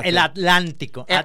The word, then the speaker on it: Atlántico: Y luego el Atlántico. – Atlántico. La T no la pronuncian.